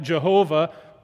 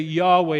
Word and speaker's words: Yahweh: It used to be that we call that Jehovah, but now we generally say Yahweh is probably the better pronunciation.